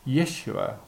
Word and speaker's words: Yeshua: Of course, in the Aramaic of the time, they were pronounced Yeshua and Yosef.